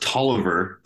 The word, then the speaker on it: Taliaferro: It's actually pronounced Tolliver, believe it or not. It's a funny old, it's a Southern name.